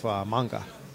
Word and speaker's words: manga: It's spelled manga, so I have to remember that it's not pronounced that way. – Right, we don't put the American A on there.